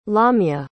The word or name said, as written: lamya